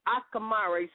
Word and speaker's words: Akamara: Only Akamare, and that's spelled A-K-A-M-A-R-A, Akamare. Say it with me, Akamare.